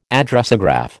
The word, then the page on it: Addressograph: American English